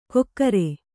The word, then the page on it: ಕೊಕ್ಕರೆ: kokkare